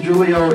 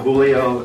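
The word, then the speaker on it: Júlio: My name is Julio. If you're wondering why it's not Julio, it's because I'm originally from Brazil. It's a hard J because it's Portuguese, so I just make it complicated for everybody else.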